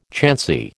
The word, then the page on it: chancy: American English